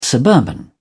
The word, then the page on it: suburban: British English